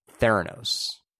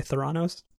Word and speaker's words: Theranos: And this is the story of Elizabeth Holmes and Theranos, which I can pronounce that correctly now because of the documentary. – I was really hoping you were going to say Theranos.